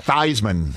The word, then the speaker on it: Theismann: Theismann. – Yep. – Rhymes with Heisman.